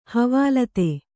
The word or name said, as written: ಹವಾಲ್ತೆ